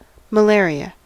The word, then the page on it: malaria: American English